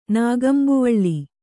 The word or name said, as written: ನಾಗಂಬುವಳ್ಳಿ